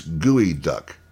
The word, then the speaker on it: geoduck: Geo is not pronounced in the usual fashion. It's pronounced gooey. So it's gooey duck.